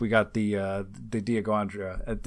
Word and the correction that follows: Dianoga: Did I say that right? I think I probably goofed that. Dianoga.